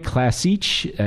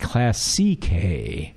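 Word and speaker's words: Classiche: Ferrari Classic, actually, it's pronounced Classique, because I'm not a linguist by any means, but Ferrari's been doing this for a long time.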